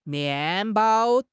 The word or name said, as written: mian4 bao1